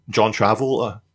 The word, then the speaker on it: John Travolta: Look at John Travolta.